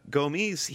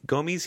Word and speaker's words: Gomis: I wonder if I said that right that time. – Possible. Only Twitter will tell us. But yeah, Gomis, see, there you go. You got it. Gomis, he's got...